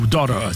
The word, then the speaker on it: Daughter: E a positividade FM 104.5 sons era boa de Pearl Jam, Daughter, ou Daughter, se você preferir, na pronúncia do inglês americano, Glenn Frey, Hit Is On, Seu Jorge, Mina do Condomínio.